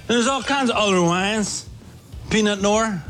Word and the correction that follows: Pinot Noir: Pinot Noir, I believe is how that's pronounced.